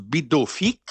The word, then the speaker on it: Pitufik: La base militaire qui s'appelle, ça s'écrit Pitufik, mais ça se prononce Bidofik, qui est là.